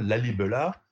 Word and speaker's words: Lalibela: Lalibela, comme ça se prononce, site absolument exceptionnel de, où ma collègue Marie-Laure Dora dirige un grand programme de fouilles depuis dix ans.